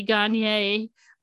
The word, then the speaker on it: Gagne: Lieutenant Matt Gagne, and I know people may be cringing at me saying Gagne, and thinking it should be Gagne, but in Maine, we pronounce it Gagne.